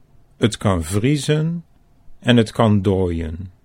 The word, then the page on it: 't kan vriezen: Dutch